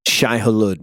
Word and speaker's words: Shai-Hulud: And it seems like the Fremen worship the sandworm, the Shai-Hulud. I don't know how to say it. – Shai-Hulud, yeah. – Shai-Hulud.